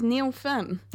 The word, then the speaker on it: Neil Finn: Rosetta told us at the top of the show that it rhymes with Schmielschmin. And of course, it is Neil Finn.